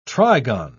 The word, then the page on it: trigon: British English